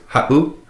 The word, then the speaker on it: ha7u: If you listen to that word ha'u, ha'u, it would be spelt H-A-7-U. If you were to take out that seven, it would be pronounced ha'u, but with the seven in there, makes that little glottal stop, that pause. So you say ha'u, ha'u.